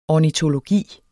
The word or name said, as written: ornitologi